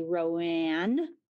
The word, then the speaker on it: Rowan: So my mom and all of her sisters are from Rowan County, North Carolina. And there it is definitely Rowan. – Rowan. Okay. – But Rowan is how we pronounce Rowan.